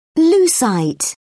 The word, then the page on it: lucite: British English